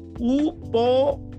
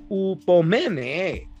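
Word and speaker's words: hupomonē: H-U-P-O-M-O-N-E-E, -E, Upomene, ¿no? Así se, así, si me acuerdo bien, así se pronuncia.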